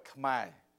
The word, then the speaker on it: Khmer: The Cambodian language, we call it Khmer.